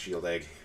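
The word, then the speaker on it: Shieldaig: We are drinking a Shield Egg. – Now, say that again. – Shield Egg single malt Scotch whiskey from Islay. I think it's a 12-year-old. – It's Scottish, so it's pronounced Shield Egg.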